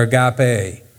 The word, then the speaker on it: agape: But the word that's used here and many other times in the Bible is agapo or agape. It's pronounced both ways.